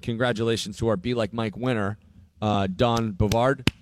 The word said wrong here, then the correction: Beauregard: Stop saying his name wrong. – He's from Greensboro. – It's Beauregard.